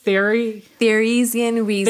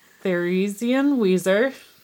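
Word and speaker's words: Theresienwiese: The couple were married on October 12th, 1810, with the festivities taking place on the 17th of October on the grounds of I wrote this out phonetically, guys, but it's still a lot. – Theresien Wiese. – Thank you. That's more German than Sam. Theresien Wiese.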